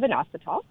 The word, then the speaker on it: Inositol: So I'm getting emails almost constantly now on a product called Inositol. I hope I'm saying that right. It's I-N-O-S-I-T-O-L. Okay, so Inositol.